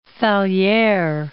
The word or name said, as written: Fallieres